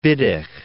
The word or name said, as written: Wittig